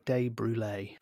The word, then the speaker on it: De Brulee: Yeah, she wrote De Brulee. She broke it down and she said it rhymes with creme brulee.